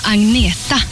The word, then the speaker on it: Agnetha: Hello, I'm Agneta. I know it's very hard for you to pronounce, but you can say Agneta or you can say Anna.